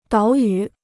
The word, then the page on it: 岛屿: dǎo yǔ